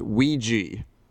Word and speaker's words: Ouija: once and for all, that it's pronounced Ouija.